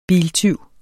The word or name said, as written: biltyv